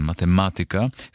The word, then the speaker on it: matematyka: Jak pisze PWN, w starannej wymowie wymienione wyrazy należy akcentować na trzeciej sylabie od końca. Zatem matematyka, fizyka, pedagogika, muzyka.